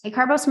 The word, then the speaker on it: acarbose: What is acarbose? Acarbose, am I saying that right? – Oh yeah.